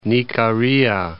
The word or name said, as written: Nikaria